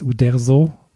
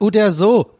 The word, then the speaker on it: Uderzo: Rest in Peace, Uderzo. Habe ich das richtig gesagt? Habe ich Uderzo. – Hab ich's richtig gesagt? – Ja, der heißt Ud Uderzo oder so. Oh, sehr gut.